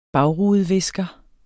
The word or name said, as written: bagrudevisker